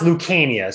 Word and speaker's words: Lucania: It's pronounced Lucania. So it's the C is a hard C. It sounds like a K.